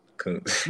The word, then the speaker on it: Kountz: Daylen Kountz, right? I got that right. Kountz. – Yep, Kountz.